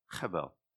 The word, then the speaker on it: Hevel: So the actual vowels and consonants will be E-V-E-L, something like that, Evel. But you have to pronounce Hevel, Hevel. You have to put that in there.